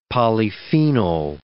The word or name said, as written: polyphenol